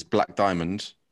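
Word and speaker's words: Blaq Diamond: The next one, I'm gonna butcher the title of it because I don't know how it's pronounced, but the artist is Black Diamond with a Q though. B-L-A-Q Diamond.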